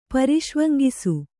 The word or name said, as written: ಪರಿಷ್ವಂಗಿಸು